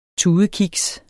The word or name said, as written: tudekiks